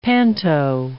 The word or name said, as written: panto